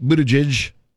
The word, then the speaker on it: Buttigieg: Transportation Secretary Pete Buttigieg. Is that how you say his name? – Sure. Yeah. I think so.